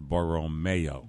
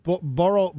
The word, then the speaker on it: Borromeo: By the way, listener Doug says it's pronounced Borromeo. – What'd I say, Borromeo? – I don't know. – And Where art thou, Borromeo? – Supposedly Borromeo. Thanks, Doug. – Borromeo.